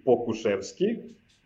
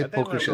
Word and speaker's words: Pokuševski: Okay, so it's Alexei Pokuševski. – Yeah, Alexei Pokuševski.